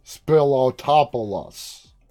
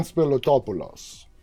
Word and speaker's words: Spilotopoulos: I would say it and I would pronounce it like Spilotopoulos. But no, it's Evan Spilotopoulos.